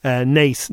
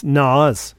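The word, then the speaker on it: Naas: Nadine Coyle hilariously called Nace Nas, right? Oh. She didn't know how to pronounce, even though she opened a shopping centre or something in Nace. She called it Nas.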